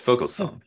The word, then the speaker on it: Vogelsong: If you're German, it does. It's Vogelsong.